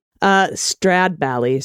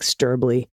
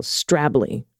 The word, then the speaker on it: Stradbally: Stradbally seems easy, no? No, it's probably something like Sturbly. Instead of Stradbally, it's almost one syllable, Strably.